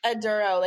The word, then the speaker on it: Aduro: Aduro, like A-D-U-R-O.